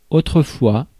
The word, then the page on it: autrefois: [o.tʁǝ.fwa]; /o.tʁə.fwa/